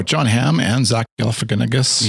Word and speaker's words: Galifianakis: Galifianakis. Right. Say it. Yeah, say it with me.